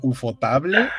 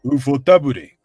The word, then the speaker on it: ufotable: Puedes suponer que lo pusiste como ufotable y después Federico dice, si es japonés, se pronuncia ufotabure.